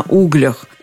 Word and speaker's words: углях: Здесь можно выбирать, что делать – сидеть как на угольях или сидеть как на углях. Ударение в любом случае на «у».